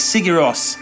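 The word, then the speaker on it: Sigur Rós: It is the unmistakable Sig Sigioro. That's not. See, I've, uh, what's wrong? I, have pronounced this so many times. It's Sigiros. with a with a couple of very very beautiful records.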